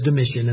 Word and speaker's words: Domitian: I've heard his name pronounced different ways. I follow a couple of different people online, listening to their histories. And there's another Reformed pastor who refers to him as Domitian. And I have never heard Domitian. And so if that is the way you think it should be pronounced, I leave it to you. I learned it as Domitian, and that's how I will pronounce his name.